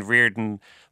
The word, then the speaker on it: Reardon: And our surname being Reardon, Mom thought the spelling was R-I-O-R, but we spell it R-E-A-R.